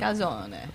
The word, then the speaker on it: calzone: Am I saying that right? – Yeah. – Calzone? – Yes. – Okay. Because some people say the calzone, but I don't think that's... – Well, in Italy, it's calzone, but...